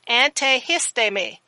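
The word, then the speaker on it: antihisteme (ἀνθίστημι): It is antihisteme, and you need to know how to spell it.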